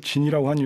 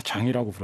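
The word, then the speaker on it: Jean: 위대한 힙합 블랙뮤직 그룹으로는 영원히 뭐 매번 언급될 수밖에 없는 그런 그룹이기도 하고 이 와이클리프 진은 거기에서 아 제가 와이클리프 진이라고 하는 이유 또 어릴 때 처음 들었을 때는 맞아요.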